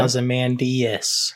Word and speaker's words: Ozymandias: Ozymandias, which is how the prequel comic says he is supposed to be pronounced.